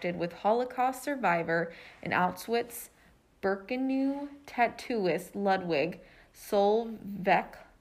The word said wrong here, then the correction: Sokolov: if I'm pronouncing that correctly. Yeah, this one right here. – Sokolov? – Sounds good.